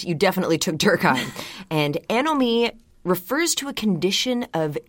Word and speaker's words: anomie: And the concept is called anomie, and that's spelled A-N-O-M-I-E. It's a French term, so I'm pronouncing it that way. It sounds a lot better in French, anomie, just rolls off the tongue.